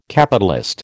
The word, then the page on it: Capitalist: American English